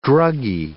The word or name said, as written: druggie